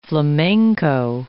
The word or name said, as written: flamenco